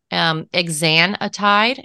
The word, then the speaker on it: exenatide: I looked at another toxicology case report in 2013 in the Journal of Clinical Toxicology, which looked at exenatide, and I hope I'm pronouncing that correctly.